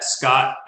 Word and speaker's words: Scott: You can email me at Scott, S-C-O-T-T, at Castle Rocks. That's K-A-S-E-L.